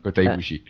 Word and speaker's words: Kota Ibushi: Allez voir parce que y a énormément de matchs géniaux, tous les Kota Ibushi, si je prononce bien son nom. – C'est ça, Kota Ibushi.